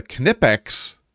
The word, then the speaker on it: Knipex: The thing I've added to my pouch in recent years, this was a relatively recent addition, is a Knipex.